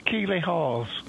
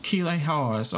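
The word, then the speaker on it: Keeley Hawes: Keely Hors. – Keely Hors.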